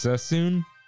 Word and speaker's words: Suisun: Tiffy Luna writes in, Suisun is pronounced Sassoon.